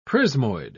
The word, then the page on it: prismoid: British English